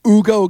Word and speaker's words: Hugo: They could have thrown in Hugo Gonzalez. Don't pronounce the H, apparently.